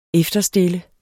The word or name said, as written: efterstille